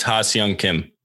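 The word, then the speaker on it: Ha-seong Kim: It's like Ha Sway Kim, I think. Back you fact check that. – I think it's Ha Seong Kim.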